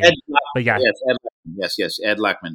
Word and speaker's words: Lachman: And then I looked it up, and Edward Lachman. I don't know if that's how you pronounce his name. – Ed Lachman. Yes, he is a terrific cinematographer.